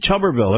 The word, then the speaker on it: Tuberville: Tommy Tuberville. Everybody knows him. He was a former Auburn coach, Tommy Tuberville. Yep. So that's how you say his name, Tuberville.